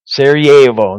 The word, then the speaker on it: Sarajevo: How do you say that word? Sarajevo, that's right, the city of Sarajevo, under siege.